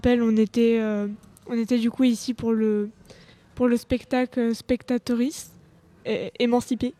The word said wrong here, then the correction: Les Spectatrices émancipées: Je vais le redire hein, parce que je ne l'ai pas très bien prononcé, les spectatrices émancipées.